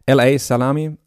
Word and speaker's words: L.A. Salami: Wäre cool, von L.A. Salami. Ich hoffe, ich habe es richtig ausgesprochen. Man spricht ihn, es hört sich immer so blöd an, wenn man das als Beispiel sagt, wie die Wurst. L.A. Salami, Day to Day, 6 Days a Week.